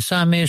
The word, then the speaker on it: Sami: Es Sami, es un nombre árabe, no viene de Samuel.